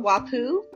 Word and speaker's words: Wapuu: So our mascot is called Wapu, and it's spelled W-A-P-U-U, and it's out of the classic anime type character. And the Wapoo is just this cuddly little Pikachu-type character, and it's holding a ball with a WordPress W on it.